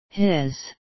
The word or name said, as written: his